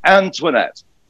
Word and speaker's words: Antoinette: Sorry, Olga, it's Antoinette. Three syllables, see? Antoinette.